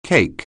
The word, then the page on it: cake: anglais